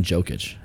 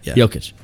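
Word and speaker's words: Jokić: I keep saying Jokic, and it's not how you say it, but I really don't care.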